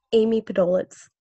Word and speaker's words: Podoletz: Amy Podoletz. – Amy Podoletz. Podoletz. Yes, Podoletz. – Oh. – Think Podo and then let's and then put it together.